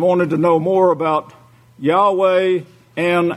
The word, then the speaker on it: Yahweh: It's pronounced in Hebrew. You could go to a Hebrew and speak Hebrew and say Yahweh. That's the way you pronounce it.